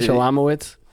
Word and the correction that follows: Shlomowitz: No, it's not Shalom. It's Shlom. – Shlomowitz. – There's no A.